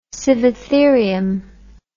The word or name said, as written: Sivatherium